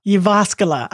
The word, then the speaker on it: Jyväskylä: Jyväskylä. – I was close. I wasn't bad. I wasn't too far off. I think my emphasis was on a different syllable there, but not too bad.